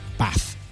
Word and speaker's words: Bath: And by the way, sorry to be pedantic, it's pronounced Bath.